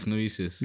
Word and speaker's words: Gnosis: Am I saying it right? Gnosis. – I don't know. I've never known. – Okay. – But it's Gnosis. – Gnosis. – And that's how I've always called it.